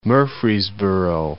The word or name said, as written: Murfreesboro